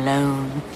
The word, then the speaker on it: Lone: Tom Lone. Rhymes with cone, phone, what? bone.